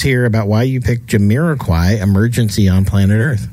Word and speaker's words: Jamiroquai: Well, first of all, I appreciate your pronouncing that, because I've been to Jamiroquai. I mean, anyway, so thank you. – You actually just got it. – Yeah, good job. – Jamiroquai.